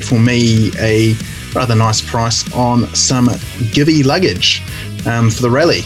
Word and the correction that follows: Givi: By the way, it's GV, but that's fine. I'll let you away with it. – GV? I've never heard it pronounced, ever. So GV luggage. I suppose that makes sense. It is Italian, I believe, isn't it? – Is that from our friends at Eurobike Wholesale?